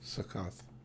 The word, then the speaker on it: Succoth: And verse 20, so they took their journey from Succoth. Is that how you say it? – Succoth.